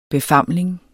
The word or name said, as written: befamling